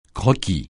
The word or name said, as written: croquis